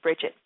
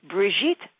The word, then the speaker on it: Brigitte: So I'm thinking of Bridget, but wasn't thinking of the French roots. Brigitte Zary is the correct way to pronounce Brigitte's name.